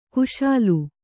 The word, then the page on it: ಖುಶಾಲ್: Kannada